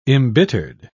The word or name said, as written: embittered